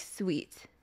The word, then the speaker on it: Swith: Swith. It's supposed to be pronounced like sweet in Korean.